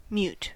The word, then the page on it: mute: American English